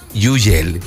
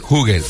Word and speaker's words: Hugel: Ahorita tenemos Moving to the Sun, que en una entrevista le preguntaban al mismo Hugel cómo se pronunciaba su nombre, y él decía que en Francia es Yugel, Yugel, en Francia. Pero como uno quiera llamarle, aunque él dice que globalmente es Hugel. O si lo quieren pronunciar como en Francia, Yugel.